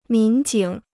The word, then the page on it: 民警: mín jǐng